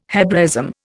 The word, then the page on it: Hebraism: Indian